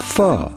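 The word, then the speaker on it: phở: it's time for a bowl of pho. – How to pronounce in Vietnamese pho? Pho. Pho.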